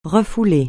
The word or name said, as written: refouler